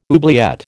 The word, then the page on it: oubliette: American English